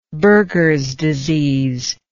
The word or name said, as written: buergers disease